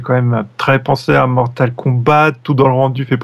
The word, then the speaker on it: Mortal Kombat: Par contre, tu prononces toujours le T à la fin de Mortal Kombat. – Oui, parce que c'est un jeu anglais. – Mortal Kombat.